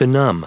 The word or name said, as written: benumb